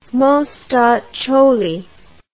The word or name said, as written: mostaccioli